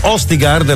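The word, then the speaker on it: Østigård: Miquel Østigard, la pronuncia è giusta? – Sì.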